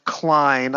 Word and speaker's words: Klein: So Klein is definitely pronounced Klein. I have relatives whose last name is Klein. We pronounce M-A-Y-A here in the States, Maya.